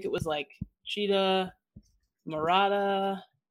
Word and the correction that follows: Chicha morada: It's made from purple corn. Yeah, Chicha Morada.